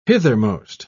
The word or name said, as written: hithermost